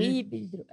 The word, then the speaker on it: híbrido: Um híbrido. É, é falei errado, você falou errado e falou todo mundo errado.